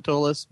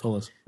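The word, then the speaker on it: Ptolus: Is the P silent? I don't know. – I think it is. – If I remember right, I think it is. I think they do call it Tolis, but Tolis, yeah. – You know, maybe people on the other side of the planet pronounce the P.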